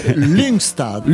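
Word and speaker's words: Lyngstad: Alors presque, oui. Euh, Linkstadt. Le Y se dit U.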